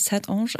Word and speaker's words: Saint-Ange: Ja, es ist Französisch. Das spricht man auch tatsächlich Saint-Ange aus.